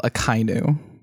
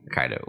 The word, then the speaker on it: Akainu: Also, it's pronounced Admiral Akainu. – Akainu, whatever. I'm just reading the portmanteau. Yeah. – No, I got what you said. Akainu, because Inu's dog.